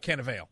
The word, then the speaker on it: Cannavale: Cannavale.